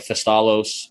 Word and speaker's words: Thestalos: And you'd have like Thestalos. I don't know if that's how you pronounce his name. – We call it Thestalos. Yep.